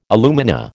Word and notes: alumina: American English

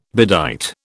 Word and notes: bedight: British English

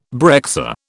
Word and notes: breccia: British English

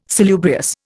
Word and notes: salubrious: Indian

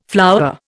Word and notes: flowerer: Indian

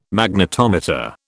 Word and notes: Magnetometer: British English

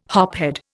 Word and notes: hophead: Indian

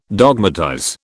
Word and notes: dogmatize: British English

